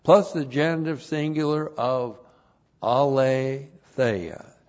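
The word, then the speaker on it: aletheia (ἀλήθεια): And aletheia, A-L, long E-T-H-E-I-A, aletheia.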